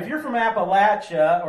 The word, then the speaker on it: Appalachia: I don't even know the right way to pronounce it technically, but culturally, it's Appalachia, like a latch.